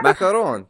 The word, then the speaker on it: macaron: What do they call them? – Macaron. – That's how you say it.